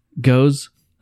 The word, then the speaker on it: Gose: This is our interpretation of Goza, pronounced Goza.